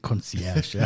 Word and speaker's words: Concierge: Ich denke mal, es hat keiner von euch zu Hause einen Concierge-Service, so wird es ausgesprochen. Es sei denn, er wohnt noch bei Mutti, dann ist Mutti sowas wie Concierge.